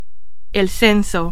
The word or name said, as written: censo